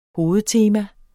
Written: hovedtema

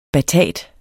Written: batat

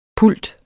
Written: PULTE

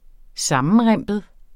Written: sammenrimpet

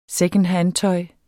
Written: secondhandtøj

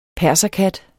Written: perserkat

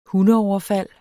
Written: hundeoverfald

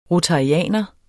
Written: rotarianer

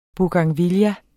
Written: bougainvillea

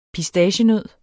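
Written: PISTACIENØD

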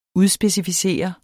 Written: udspecificere

